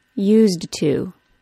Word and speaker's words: used to: In the phrase used to, we have the Z sound of the word used, then the D sound of the ED ending of the word used, then the link into the T sound of the word to. Fully pronounced, it is used to.